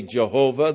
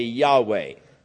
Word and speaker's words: Yahweh: There are those who say Jehovah. There are those who say Yahweh. All we have are the consonants, YHVH. They left out the vowels so that we do not know what was the actual pronunciation. But most scholars conclude that it was Yahweh.